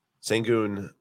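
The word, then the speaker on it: Şengün: This is the guy that everyone who is buying Jalen Green probably wishes that they're buying instead is Alperen Sengun. Sengun, however you pronounce his name. – Yeah, that was right.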